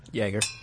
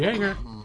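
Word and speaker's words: Joerger: So there's a growing discord between the Sacramento Kings and their head coach, Dave Jaeger. – That's how you say it? Okay. – Jaeger.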